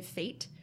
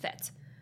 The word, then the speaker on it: fête: So I think that, like, some people will say fate for, but it's, I, based on my French, it's pronounced fête.